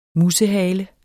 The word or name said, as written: musehale